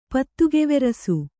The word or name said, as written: ಪತ್ತುಗೆವೆರಸು